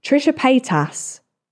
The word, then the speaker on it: Trisha Paytas: Trisha Paytas.